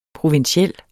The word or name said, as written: provinsiel